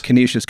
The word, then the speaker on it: Canisius: You went to, is it pronounced Canisius? – Canisius College, yeah.